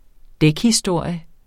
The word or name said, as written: dækhistorie